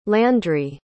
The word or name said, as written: landry